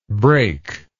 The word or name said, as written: breakless